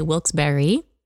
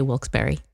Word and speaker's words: Wilkes-Barre: Yeah, some people say Wilkes-Barre, say Wilkes-Barre. The locals say Wilkes-Barre.